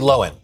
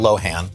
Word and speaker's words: Lohan: Like, it's Lindsay Lohan, not Lohan.